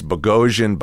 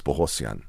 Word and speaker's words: Bogosian: You know, it's like, it's Bogosian, Bogosian. – What is Bohosian. – It? – Well, the correct pronunciation is Bohosian.